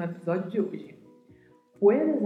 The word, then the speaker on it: puedes: Cuidado ao pronunciar, pois a letra U precisa ser dita de maneira bem clara, puedes, e o D, que é pronunciado com este som mesmo, de D e não de D, como nós falamos no português. Puedes.